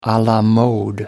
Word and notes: a la mode: American English

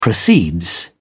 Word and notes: proceeds: English; British English

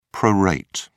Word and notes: prorate: British English

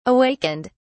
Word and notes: awakened: British English

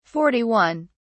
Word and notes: 41: British English

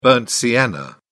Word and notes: burnt sienna: British English

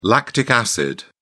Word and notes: lactic acid: British English

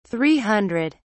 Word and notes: three hundred: British English

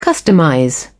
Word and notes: customize: British English

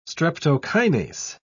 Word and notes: streptokinase: British English

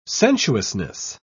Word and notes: sensuousness: British English

